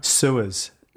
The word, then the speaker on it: Suez: Suez. S-U-E-Z.